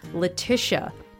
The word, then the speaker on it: Letitia: She offers the pronunciation up front. She puts it right on her website. Letitia. Tish rhymes with wish.